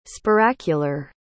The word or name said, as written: spiracular